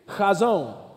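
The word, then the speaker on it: chazon (חזון): One, two, three, chazon. Now listen, if you're saying this correctly, the person in front of you should feel a little moisture on the back of their head when you get it out.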